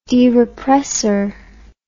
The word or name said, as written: derepressor